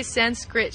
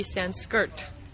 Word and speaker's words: Sanskrit: This is a vowel that actually is IR, not RI. So actually Sanskrit should be Sanskirt. Like skirt. But because us Westerners pronounced it so poorly, it got stuck Sanskrit.